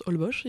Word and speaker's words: Holbox: il bah Ah et un autre truc ah euh, c'est euh, au Mexique, je suis allée à Holbox, ça s'écrit Holbox mais ça se prononce Holbox il me semble.